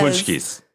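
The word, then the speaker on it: pączki: It's like here in Chicago, we do poshkis. – Yes. – Punchkis. They're pronounced punchkis, which is Polish, and that's just like a fatty donut filled with cream.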